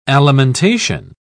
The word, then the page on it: alimentation: British English